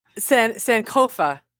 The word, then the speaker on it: Sankofa: You're like on page 200 of, it starts with an S, but I didn't know how to pronounce it. – Sankofa. – Yeah.